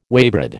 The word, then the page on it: waybread: American English